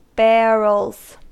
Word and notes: barrels: American English

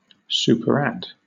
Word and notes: superadd: British English

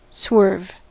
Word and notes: swerve: American English

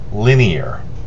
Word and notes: linear: American English

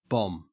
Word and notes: bomb: Australian English

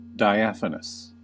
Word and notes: diaphanous: American English

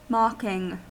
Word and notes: mocking: American English

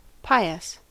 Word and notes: pious: American English

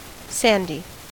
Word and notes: sandy: American English

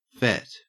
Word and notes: fet: Australian English

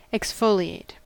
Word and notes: exfoliate: American English

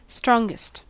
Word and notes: strongest: American English

